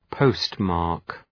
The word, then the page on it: postmark: ‘pəʋst,mɑ:rk